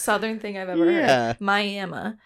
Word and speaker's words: Miami: So Picher, Oklahoma is located 8 miles north of, and don't butcher me on this, it's spelled like Miami, Florida, but it's pronounced Miami. That's where my coworker's from. She's from Miami. So I have a previous resident telling me it's not Miami, it's Miami.